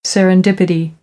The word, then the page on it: serendipity: American English; ɾ